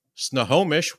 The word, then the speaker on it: Snohomish: The names are too much for me being, I guess, from Texas, but coming to us all the way from Snohomish, Washington, we have the founder of Kula Cloth, Anastasia.